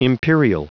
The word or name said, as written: imperial